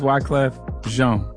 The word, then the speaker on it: Wyclef Jean: No, it's Wyclef Jean. – Yeah. – All right, praise the Lord. – Yeah, it's spelled like Jean, though. – It is, you know. – Yeah, J-E-A-N is Jean in America.